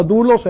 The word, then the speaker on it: doulos: Esta palabra dulos en el griego se escribe así en el español, doulos, quiere decir esclavo.